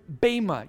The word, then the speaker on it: bema: The Greek word translated there as judgment seat, okay, is the word bema. You may have heard me say that word last Sunday if you were here. It's spelled B-E-M-A, and the bema is not the seat where the judge sits to render a verdict whether guilty or innocent.